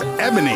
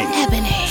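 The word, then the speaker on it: Ebxny: Now, her name is spelled just as unique as her music. It's E-B-X-N-Y. Don't get it wrong. – Let's get it. – Right. But you say it as Ebony. – Ebony.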